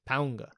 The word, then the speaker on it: Paunga: Paunga. There's an N somewhere, apparently. – No. Yeah, my understanding is that is how it's pronounced, yeah.